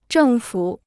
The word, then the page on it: 政府: zhèng fǔ